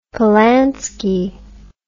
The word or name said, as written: polanski